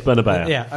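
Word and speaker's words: Bernabéu: And second thing was, going back to the Bernabeu, as you pronounce it. – Please, Bernabeu. – Okay. – Please. – All of your pronunciations are spot on.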